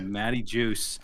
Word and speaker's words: Matty Juice: Matty Juice. What's going on, everybody? Bringing together the Fresh Juice. I said it right this time. I said Matty Juice.